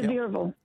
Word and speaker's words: Sevierville: Sevierville, Tennessee. Am I saying it right? – Sevierville.